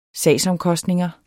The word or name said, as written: sagsomkostninger